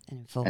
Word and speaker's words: Enninful: Is it Enninful? – Yes, Enninful. Enninful.